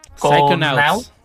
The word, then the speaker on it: Psychonauts: Vamos a ver si es Psych. – Psychonauts.